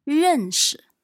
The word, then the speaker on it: 认识: 认识。